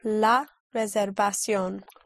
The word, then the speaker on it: reservación: Reservation. The V is pronounced with your lips, with like a soft B. Reservation. – Reservation. – Right. The reservation would be? – La reservación.